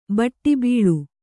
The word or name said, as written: ಬಟ್ಟಬೈಲು